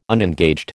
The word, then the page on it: unengaged: American English